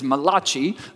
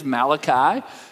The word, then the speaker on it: Malachi: Some people have mispronounced his name as Malachi, like some Italian prophet, but his actual name is Malachi.